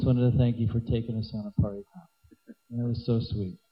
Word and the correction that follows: parikrama: And we didn't even parikrama. – Parikrama, whatever. – It's a long A in the end, so you can't drop it.